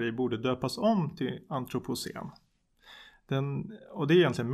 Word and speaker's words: antropocen: Det har börjat talas allt mer om ett begrepp, nu vet jag inte riktigt om jag uttalar rätt, antropocen. – Ja, antropocen ja.